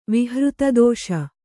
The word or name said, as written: ವಿಹೃತದೋಷ